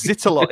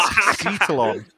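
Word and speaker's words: Xitilon: No, it's Zitalon. It's spelled Exitalon, but it's pronounced Citalon.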